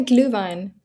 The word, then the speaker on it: Glühwein: Yeah, Glühwein.